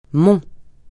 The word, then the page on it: mont: francais